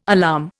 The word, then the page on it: alarm: Indian